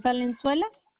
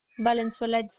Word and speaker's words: Valenzuela: Diane Valenzuela, D-I-A-N-E, Valenzuela, V-A-L-E-N-Z-U-E-L-A. – You said G-U? – It's V-A-L-E-N-Z as in zebra, U. E-L-A.